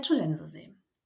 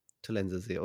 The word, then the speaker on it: Tollensesee: Der Tollensesee. – Tollensesee, okay, so spricht man es richtig aus.